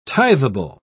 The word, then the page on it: tithable: British English